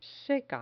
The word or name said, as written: seca